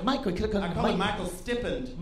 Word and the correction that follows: stipend: Am I pronouncing that right? I've only read that word before. – Stippend. – Stippend. – Who can say Stippend? Say it out loud if you know it. Stipend. Oh, right.